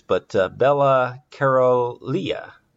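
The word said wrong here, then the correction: Károlyi: Caroli. There you go.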